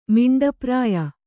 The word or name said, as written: ಮಿಂಡಪ್ರಾಯ